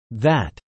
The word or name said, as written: VAT